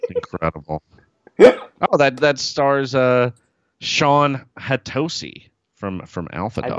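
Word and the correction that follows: Hatosy: I believe it's pronounced Hattasi.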